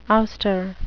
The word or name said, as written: ouster